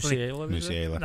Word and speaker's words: Musiala: Was it Musiala? I can't say his name properly. – Musiala. – That's the one.